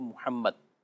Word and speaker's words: محمد: تو محمد کا جو تلفظ ہے وہ محمد یہ ہے.